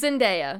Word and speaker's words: Zendaya: It stars Zendaya! – Yeah, it's a newer Netflix show with Zendaya. Zendaya? Whatever. Is it Zendaya or Zendaya? – It's Zendaya.